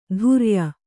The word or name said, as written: ತುರ್ಯ